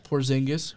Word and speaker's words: Porzingis: The Mavericks, they have Kristaps Porzingis, who's finally healthy. – I can't believe you pronounced that correctly. I wouldn't even know how to say that.